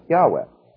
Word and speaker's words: Yahweh: And the most probable way it should be pronounced is to put in an A after the Y, Y-A-H, then W-E-H, which we come with Yahweh.